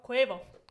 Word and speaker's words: Quavo: Quavo. – Quavo. – Hey, Quavo. – Quave mende. Quave. You could have beat up a Quavo. Suave. Quavo, sorry.